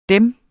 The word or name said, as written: Dem